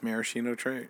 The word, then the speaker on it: maraschino: I need to go get maraschino cherries. – Mm-hmm. – That's how you say it? – Yes. – I feel like you're telling me that and that's not right. – No, it's maraschino cherries.